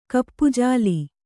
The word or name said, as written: ಕಪ್ಪುಜಾಲಿ